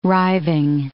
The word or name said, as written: riving